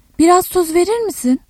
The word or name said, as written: tuz